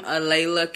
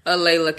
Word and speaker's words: A'laila: So what you would type in is Breaking the Spell and then put my name, Alayla Carroll. Or you can just type in my name, Alayla Carroll. Don't forget the apostrophe.